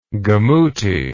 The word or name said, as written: gomuti